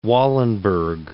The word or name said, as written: Wallenberg